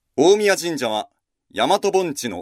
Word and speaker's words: 大神神社: 大きい神の神社と書いて、大神神社ではなく、大宮神社と読むのは、古来、三輪の大物主の神は神の中の神であり、神といえば直ちに三輪の大物主の神を指したためと思われます。